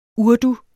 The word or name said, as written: urdu